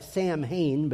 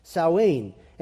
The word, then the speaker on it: Samhain: It's not spelled like that at all. It looks like Samhain, but it's Samhain.